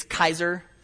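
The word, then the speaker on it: Kaiser (Καῖσαρ): And, and by the way, in, in Greek, it's Kaiser. Um, and that's, that's how you pronounce it in, in, in, Greek. When it comes over to English, we soften uh, that syllable.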